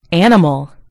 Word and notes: animal: British English

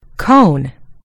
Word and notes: cone: American English

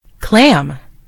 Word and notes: clam: American English

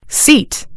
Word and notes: seat: British English

